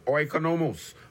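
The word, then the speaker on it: oikonomos (οἰκονόμος): The Greek word is oikonomos. Oikonomos. Spelled O-I-K-O-N-O-M-O-S. Oikonomos. I spell it again. O-I-K-O. N-O-M-O-S, stewards, oikonomos, and it literally means house manager.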